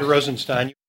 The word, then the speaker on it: Rosenstein: Good morning, I'm Rod Rosenstein.